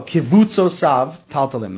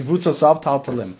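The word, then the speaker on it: Kivutzosav Taltalim (קווצותיו תלתלים): Amar HaKasuv, that the Pasuk says in Shir HaShirim, Kitsusot, Kotzosav Taltalim. Oh, Kivutzosav Taltalim. That's the way to pronounce it. Kivutzosav Taltalim.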